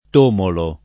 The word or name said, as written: tomolo